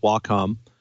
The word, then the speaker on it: Wacom: Wacom is pronounced Wacom, and is derived from the Japanese symbol for harmony, wa, and the first syllable of computer, kum.